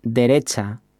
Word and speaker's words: derecha: Finally, we have derecha, which means right. Let's break it down by syllable. De, re, cha. And hear it once again. Derecha.